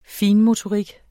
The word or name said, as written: finmotorik